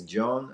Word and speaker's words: Jhon: My name is John. The first name spells J-H-O-N.